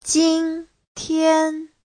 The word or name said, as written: jin1tian1